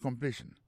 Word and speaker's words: completion: Sentence completion. Ça nous a dit dans la sentence, c'est que l'accent tombe sous sen, sous premier syllabe là. Sentence completion. Pour completion dans lui-même, accent tombé sous deuxième syllabe là.